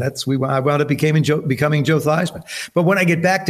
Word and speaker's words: Theismann: She says, the correct pronunciation is Theismann. And actually what they want to do is closer to it than what we have now.